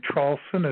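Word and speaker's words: Traulsen: My name is Hugh, H-U-G-H. Last name Trolson is spelled T like Tom, R-A, U-L-S like Sam, E like Edward, N like Nancy.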